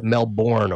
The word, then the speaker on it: Melbourne: They actually pronounce it Melbourne over here.